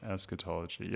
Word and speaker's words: eschatology: On your website, you say that you're primarily concerned with eschatology. Is that how it's pronounced? Okay, eschatology, yeah.